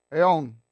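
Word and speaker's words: aiōn: Aeon. A-I, long O-N.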